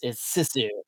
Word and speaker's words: Sisu: I'm pronouncing that wrong. It's Sisu.